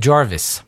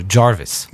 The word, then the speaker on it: Gervais: Ici, en Louisiane, c'est Jarvis. je connais des gens, euh, c'est la belle famille de mon beau-frère. Et c'est Gervais, ça s'écrit Gervais, mhm. mais ils, il prononcent ça euh, Jarvis.